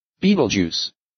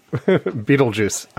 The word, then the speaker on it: Betelgeuse: Beetlejuice. Beetlejuice. Beetlejuice. – Yeah, no, you're right. – Beetlejuice.